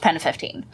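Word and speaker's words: PEN15: Well, it would be pronounced Pen 15.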